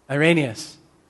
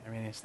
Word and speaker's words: Irenaeus: And I would just borrow from, I think his uh, name is St. Irenaeus, or Irenaeus. I'm not sure if I'm pronouncing it right. I think it's Irenaeus. Help me. Irenaeus. So, second time. Is that right? Okay, Irenaeus.